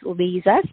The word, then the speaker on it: Liza: And so my name is Lisa, spelled like Liza, Lisa Marie Garcia.